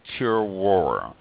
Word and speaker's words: terroir: The word is pronounced roughly like terroir, and it's French.